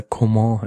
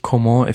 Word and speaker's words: Coman: Und endlich wird Kingsley Coman, beziehungsweise Coman, richtig ausgesprochen. Das hat sich meiner Wahrnehmung nach erst am Wochenende jetzt durchgesetzt. Beim Spiel gegen Darmstadt habe ich es zum ersten Mal von eigentlich allen Reportern gehört, dass er Coman heißt.